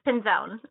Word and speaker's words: Pinzon: Well, Cindy, did I say your last name right? – Yes, Pinzon. – Pinzon, okay.